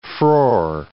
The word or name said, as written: frore